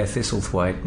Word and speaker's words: Thistlethwayte: Yeah, Thistlethwayte.